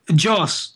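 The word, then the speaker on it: Jos: Joss.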